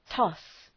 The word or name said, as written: toss